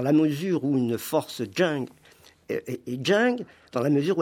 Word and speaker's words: Zheng: Donc, Zheng et Qi, mais je le prononce très mal. – C'est Zheng et Qi.